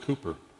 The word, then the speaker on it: Cowper: It's written Cowper, C-O-W-P-E-R, but it's pronounced Cooper.